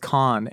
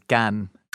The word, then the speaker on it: Cannes: I had an argument where people are can. Like, they're like, it's can, and some people are like, no, it's con. And I'm like, no, the French vowel sounds are just different. There's no proper, the only way to pronounce it is in French, which is Cannes.